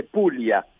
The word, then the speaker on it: Puglia: Puglia se dice en español, pero en italiano se dice Puglia.